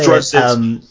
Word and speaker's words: Straczynski: Straczynski, however you say Straczynski.